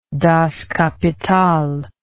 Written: Das Kapital

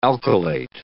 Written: alkylate

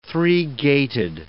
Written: three-gaited

